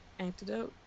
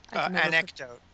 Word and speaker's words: anecdote: An antidote? – Anecdote.